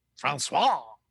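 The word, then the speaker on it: François: Francois. – F-R-A-N-C-O-I-S.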